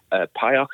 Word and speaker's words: PAOK: And yeah, Greek team, PAOK, I believe is how you pronounce it, P-A-O-K.